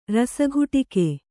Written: ರಸಘುಟಿಕೆ

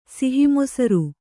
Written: ಸಿಹಿಮೊಸರು

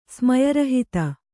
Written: ಸ್ಮಯರಹಿತ

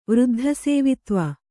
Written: ವೃದ್ಧಸೇವಿತ್ವ

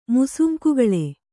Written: ಮುಸುಂಕುಗಳೆ

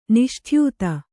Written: ನಿಷ್ಠ್ಯೂತಿ

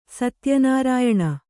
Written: ಸತ್ಯನಾರಾಯಣ

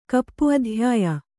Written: ಕಪ್ಪುಅಧ್ಯಾಯ

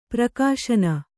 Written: ಪ್ರಕಾಶನ

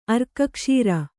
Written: ಅರ್ಕಕ್ಷೀರ

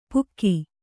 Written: ಪುಕ್ಕಿ

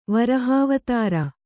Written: ವಿರಹವಿಧುರ

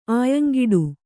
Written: ಆಯಂಗಿಡು

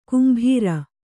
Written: ಕುಂಭೀರ

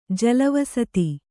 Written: ಜಲವಸತಿ

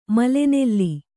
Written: ಮೇಲೆನೆಲ್ಲಿ

